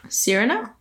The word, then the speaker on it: Cyrano: There's, how do you pronounce that? – Cyrano? – Okay, that's what it is.